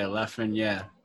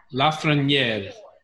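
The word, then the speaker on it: Lafrenière: Yeah, Lafreniere. – Lafreniere.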